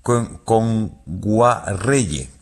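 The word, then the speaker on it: Kngwarreye: Emily Kame Nguayere, lo he dicho mal. – Emily Kame. – Con Guarreye. – Emily Kame con Guarreye, sí, sí.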